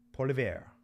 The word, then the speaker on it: Poilievre: Pierre Polivier. I'm going to need to work on my French name pronunciations. Pierre Poilievre is a man who has announced that he will be running for prime minister against Justin Trudeau.